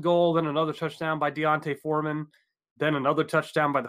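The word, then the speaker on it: D'Onta: Yeah, I looked up pronunciation and it was Deontay. – It is Deontay.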